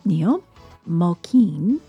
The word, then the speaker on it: Niall Mulkeen: Niall Mulkeen did I say it right? – Yes, you did.